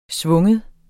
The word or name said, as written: sving